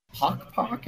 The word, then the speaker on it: Pok Pok: It's called, I'm probably butchering it, Pok Pok? – That's right.